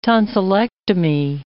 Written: tonsillectomy

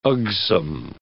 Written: ugsome

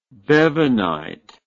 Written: Bevanite

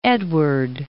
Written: Eadward

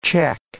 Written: cheque

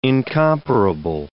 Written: incomparable

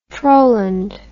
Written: troland

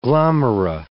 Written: glomera

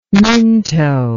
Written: minto

